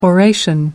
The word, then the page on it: oration: American English